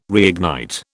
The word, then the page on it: reignite: British English